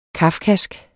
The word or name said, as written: kafkask